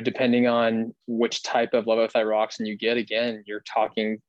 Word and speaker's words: levothyroxine: And let's talk about thyroxine, levothyroxine. Did I pronounce that right? Levothyroxine? – Levothyroxine. That's correct. Yeah.